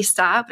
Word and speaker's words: Stott: And you guys can find me on socials at Nikki Stott, but I spell it all weird. It's N-I-K-K-I-E-Y, and then it's S-T, not C. My whole life, everyone says my last name is Scott, but it's S-T-O-T-T.